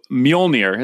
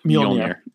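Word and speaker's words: Mjölnir: So Mjolnir is how you say that. The name of the Mjolnir.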